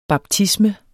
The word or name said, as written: baptisme